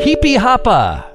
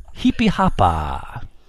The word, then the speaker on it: Jipijapa: Heepy Hoppa. Heepy Hoppa. That's spelled J-I-P-I-J-A-P-A. – What? – Heepy Hoppa.